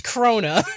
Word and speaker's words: Krone: A tall ass dude named Miss Krona. I hate her name because in the subtitles it's spelled K-R-O-N-E. – Yeah. – But they say Krona.